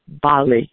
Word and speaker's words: Bale: And you say your name, you pronounce it Bali? – Bali, yes. B-A-L-E.